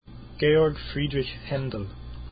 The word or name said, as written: haendel